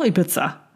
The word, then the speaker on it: Ibiza: Die Frau äh, da bei Google, die das ausspricht, sagt auch Ibiza.